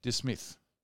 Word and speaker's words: Dismyth: Dismyth. – Yeah, I reckon you're right. It's like Portsmouth, but with a dis.